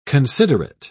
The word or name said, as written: considerate